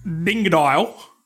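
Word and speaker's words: Dingodile: Dingadile. Because you have to say it like that.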